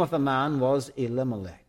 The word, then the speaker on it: Elimelech: The word Melech there means king. We pronounce it Elimelech.